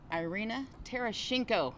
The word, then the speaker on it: Irina Tereshinko: We're here at Beer City Open here in Grand Rapids, Michigan, with the one and only Irina Tereshinko.